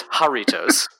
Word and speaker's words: Jarritos: I believe last time we talked about them, we both pronounced it Yarritos. – Yeah, I got a text message. Did you? – I did not, but it is Jarritos.